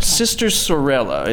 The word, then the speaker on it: Sister Sorella: Sister Sorella, am I pronouncing it correctly? – That is it.